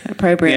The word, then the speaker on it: opprobrium: Opprobrium. – Very good. Opprobrium.